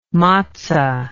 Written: matzah

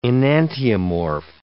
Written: enantiomorph

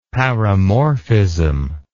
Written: paramorphism

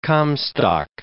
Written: Comstock

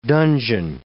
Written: dungeon

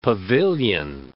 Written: pavilion

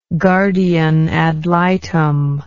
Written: guardian ad litem

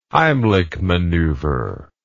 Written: heimlich maneuver

